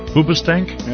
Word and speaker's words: Hoobastank: Hoobastank? – Yep.